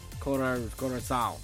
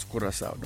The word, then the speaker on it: Curaçao: And then, Curaçao. – It's Curaçao.